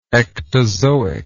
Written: ectozoic

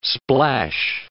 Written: splash

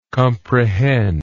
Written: comprehendingly

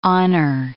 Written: honor

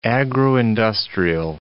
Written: agro-industrial

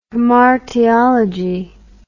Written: hamartiology

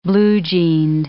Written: blue-jeaned

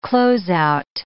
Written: close out

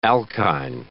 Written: alkyne

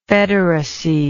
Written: federacy

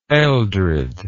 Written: Eldred

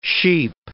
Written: sheep

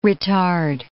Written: ritard

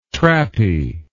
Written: trappy

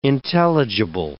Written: intelligible

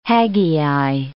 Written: Haggai

